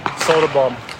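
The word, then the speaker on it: Söderblom: Soderblom or Soderblom. – Okay, which one do you prefer? Pick it. – Soderblom. – That's what it is.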